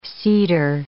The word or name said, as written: cedar